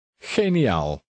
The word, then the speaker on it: geniaal: In number six, the G is pronounced the Dutch way. – Geniaal.